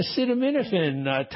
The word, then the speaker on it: acetaminophen: Study this week about acetaminophen. Have I pronounced that right? – Acetaminophen. Close.